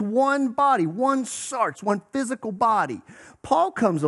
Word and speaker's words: sarx: The Greek word is sarx. Let me hear you say sarx. That's the way it's spelled, S-A-R-X.